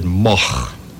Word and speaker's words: Moog: Uh, and in, in, Holland you would pronounce it Moch. Now that's a little bit too much yeah, yeah, yeah, to expect people to do it here. Uh, in Germany, Uh, it's a fairly common name, and there it's pronounced Moog.